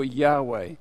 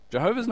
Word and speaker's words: Yahweh: Jehovah's not the best, but it means the same thing. It's the older, they thought that's how you pronounced it, but it's not the correct pronunciation.